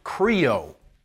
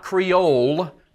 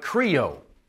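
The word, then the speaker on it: krio (χρίω): Now, the word anoint in the New Testament is a little Greek word pronounced krio. Not creole, but krio. C-H-I-R-O.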